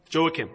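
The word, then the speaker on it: Jehoiakim: So, how do you say this, Dad? Who's Rehoacim? Joachim? Joachim, okay.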